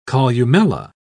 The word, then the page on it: columella: British English